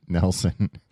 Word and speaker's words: Nilsson: It's pronounced Nelson.